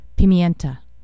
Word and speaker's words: Pimienta: And you also heard Lito Pimentena. Man, you know when you pronounce it wrong? It's terrible. It hurts your ears. Pimienta. Lito Pimienta, off her album Miss Columbia.